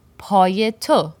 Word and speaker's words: پایت: پای تو. The most shortened conversational version, پات, would only have one syllable. پات. And the version that Rumi uses, پایت, has two syllables. پایت.